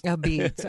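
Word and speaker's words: apizza: And so they still spell it a pizza, but it's pronounced a beets. – A beets.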